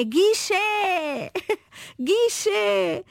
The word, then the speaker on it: guiché: Então a pronúncia correta, de acordo com as nossas obras de referência, é guichê. Guichê. Guichê.